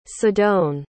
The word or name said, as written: sidone